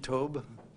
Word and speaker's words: Tob: I think that's the right pronunciation, Tob, T-O-B.